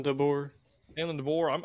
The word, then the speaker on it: DeBoer: And then the new coach, is it DeBoer? Is that how you say it? – Kalen DeBoer.